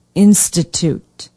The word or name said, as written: institute